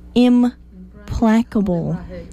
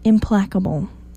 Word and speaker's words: implacable: Implacable. Implacable. That's how it says to pronounce it. I-M-P-L-A-C-A-B-L-E. Implacable. It looks like it should not be that, but someone or something described as implacable is not capable of being appeased or changed.